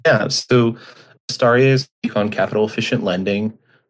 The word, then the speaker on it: Astaria: Astaria.